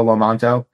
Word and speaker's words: Lomanto: So just search for Diego Lomanto.